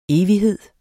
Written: evighed

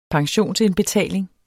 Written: pensionsindbetaling

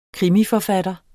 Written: krimiforfatter

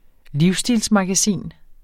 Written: livsstilsmagasin

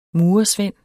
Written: murersvend

